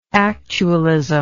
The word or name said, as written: actualism